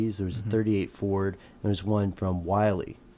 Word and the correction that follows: Willys: um, am I saying that right? I think it's Willys.